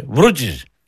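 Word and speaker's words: Βρούτση: Έχει ο κύριο Βούρτσικο, υπουργό αθλητισμού. Τα καλαβέ. – Ποιο να, τον Βρούτσι. – Το υπουργό αθλητισμού, κύριο Βούρτσι. – Όχι Βρούτσι, ρε. Βρούτσι.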